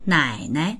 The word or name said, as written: nai3nai